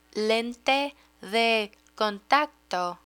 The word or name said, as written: lente de contacto